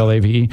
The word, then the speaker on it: Olave: Because let's see, after recovering from ACL surgery, Williams was supposed to be the Lions' version of Drake London, Chris Olave. Am I saying that right? – Yeah. – Olave or Garrett Wilson, the three receivers drafted just ahead of him.